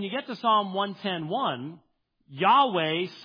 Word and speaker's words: Yahweh: And the problem is, because we don't render it as Yahweh, or as we slaughter it in English, Jehovah, it could not have been that. That's a three-syllable word. It's only a two-syllable word.